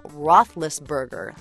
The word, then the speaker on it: Roethlisberger: Ben Roethlisberger, that's right, I said it correctly, led the Steelers to a 38-7 win over the Ravens.